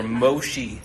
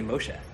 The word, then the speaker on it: Moshe: And he said, Moshe. And I was like, that's more impressive, because everywhere I go, I'm Mosh or Moshi. Like, people don't know how to say Moshe.